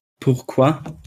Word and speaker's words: Pourquoi: Pourquoi? – Perfekte Aussprache, perfekte Vokabelkenntnis fast.